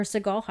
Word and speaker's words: Siegel: Anyway, so when the show was canceled back in 1989, like I said before, a producer named Philip Siegel, or Seagal, however you want to say his name. – Yeah. – Philip Siegel.